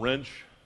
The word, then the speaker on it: Wrench: My name is Bob Wrench. Easier way to remember the pronunciation of my name when you see Wrench. It's pronounced like the tool.